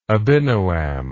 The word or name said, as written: Abinoam